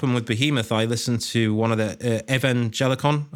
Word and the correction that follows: Evangelion: I can't pronounce that Evangelion. – Word. – Evangelion.